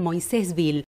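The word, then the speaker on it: Moisés Ville: Bueno, primero vamos a comenzar por el nombre, porque yo las mencioné como que están en Moisésville. ¿Cuál es la pronunciación correcta? – Bueno, la verdad que lo dijiste muy bien, pero hoy todos lo conocen por a nuestro pueblo Moisésville, que es una derivación que quedó después del original, vamos a decir.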